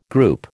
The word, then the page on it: group: American English